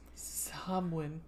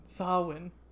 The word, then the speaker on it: Samhain: Samhain, a Gaelic word pronounced Samhain. Samhain. So, yeah, Samhain.